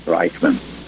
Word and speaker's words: Reichmann: and nobody could spell my German name Reichman. I always had to spell it out as R-E-I-C-H-M-A-N-N.